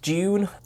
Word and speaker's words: Dune: So, do you know what rhymes with Dune? – prune. – Guess again. – Is it Honest Andy's Discount Prune Show? – No. – Oh, no, no. Moon. – It is indeed moon.